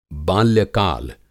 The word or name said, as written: बाल्यकाल